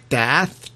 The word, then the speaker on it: Daath: Did I say that right? Daath? D-A-A-T-H? – You said it all correct.